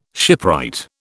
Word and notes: shipwright: British English

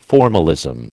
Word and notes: formalism: American English

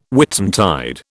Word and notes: Whitsuntide: British English

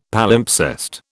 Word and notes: Palimpsest: British English

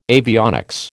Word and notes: avionics: American English

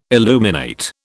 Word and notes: illuminate: British English

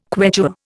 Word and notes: Quechua: Indian

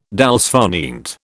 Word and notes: dolce far niente: British English

